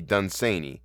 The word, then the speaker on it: Dunsany: and they all unequivocally said it should be Dunsany.